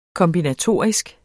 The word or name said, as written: kombinatorisk